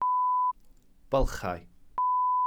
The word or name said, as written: bylchau